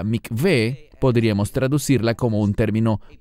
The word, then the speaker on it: Mikveh: Normalmente los mesiánicos que hablan inglés lo pronuncian como Mikvah. No es Mikvah, es la palabra Mikveh.